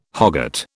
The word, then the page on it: hogget: British English